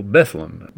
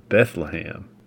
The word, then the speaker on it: Bethlehem: And just so you know, that's how the folks up in Bardstown pronounce their school, Bethlehem. Most of us are more accustomed to the pronunciation Bethlehem.